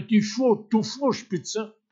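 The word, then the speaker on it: Dufourspitze: Also französisch ausgesprochen, eine Dufour-Spitze.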